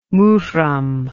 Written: Murom

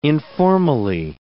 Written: informally

